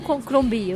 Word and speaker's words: Crombie: A gente nunca sabe qual que é a sílaba tônica, né? Mas é Crombie, é Crombie.